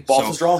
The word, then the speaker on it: Baltusrol: But yeah, the course is a Baltusrol Golf Club in Springfield, New Jersey. And it took me about, I don't know, 2 or 3 days before I could actually say that correctly. – Baltusrol, so, yeah.